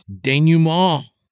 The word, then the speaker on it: denouement: Denouement.